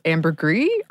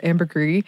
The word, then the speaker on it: ambergris: One that I had never heard of before is ambergris, or maybe ambergris. I got two different pronunciations, but we're going to go with ambergris, which is a mixture of whale poop, squid beaks, and fatty secretions.